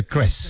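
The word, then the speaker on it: Kreß: El doctor Klaus Kress, que se escribe K-R-E como una B, ¿no, Juan Pablo? Pero se pronuncia Kress. – Es alemana, que es como una doble S.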